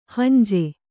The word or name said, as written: ಹುಂಜಿ